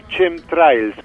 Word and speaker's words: chemtrails: la de los chemtrails o chemtrails, como se pronunciaría en castellano.